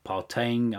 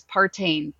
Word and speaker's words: Partain: And can you, just for those people who might not know how to spell Partain or Regina, can you just give us a little bit more how we can find you on LinkedIn then?